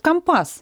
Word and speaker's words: компас: Ну, кстати, вот с ударениями это такая история неоднозначная, потому что мы говорим Мурманск, да, а моряки говорят Мурманск. То есть у них вот это такая культура. Мы говорим компас, они говорят компас.